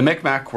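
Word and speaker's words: Mi'kmaq: the Micmac, sorry, that's the right pronunciation this time, the Micmac were notably,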